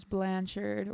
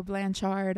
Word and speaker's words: Blanchard: That one was also spooky, but today we are doing a deep dive on Gypsy Rose Blanchard, or Blanchard. I've heard it's pronounced both ways.